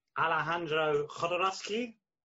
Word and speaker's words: Alejandro Jodorowsky: Alejandro Jodorowsky.